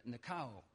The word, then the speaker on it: nikao: In the Greek, it's nikao. Okay? Phonetically, it's spelled N-I-K-A-O. Nikao.